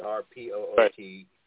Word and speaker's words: Harpootlian: Vic Harpootlian. Now, I don't know how to pronounce it. It's Harpootlian.